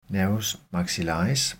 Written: n. maxillaris

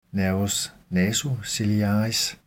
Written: n. nasociliaris

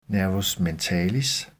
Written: n. mentalis

